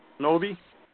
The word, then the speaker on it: Honobia: Uh, Hanobi. – Yeah, it's with a Y. It's it's spelled I A, but for some reason it's pronounced with a Y.